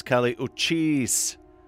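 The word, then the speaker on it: Kali Uchis: From Alexandria, Virginia, of Colombian American roots, that was Cali Uchis. So I got the pronunciation of that name finally right, as I understand the international pronunciation guide instructions I got.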